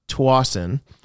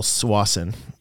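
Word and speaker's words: Tsawwassen: So the town is called Tawasin. Americans call Swasin because it's TS at the beginning.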